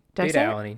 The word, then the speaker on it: beta-alanine: Beta-alanine, yeah.